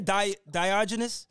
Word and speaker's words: Diogenes: And you know, when I look at the name, how you pronounce it again? Diogenes? – Yeah.